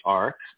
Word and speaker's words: Arch: So, you know, I was playing around with words and it was short for archive, but pronounced arc. But some people call it arch and I'm fine with that because I like arches.